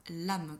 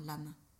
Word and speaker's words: LAM: La syllabe qui est rattachée, c'est la syllabe lame, qui s'écrit L-A-M, mais qui se prononce comme si tu mettais un E à la fin, lame.